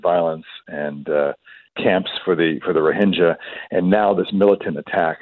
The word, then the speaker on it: Rohingya: I'm glad you corrected me because I had always heard Rohingya, so Rohingya is the better way of pronouncing it.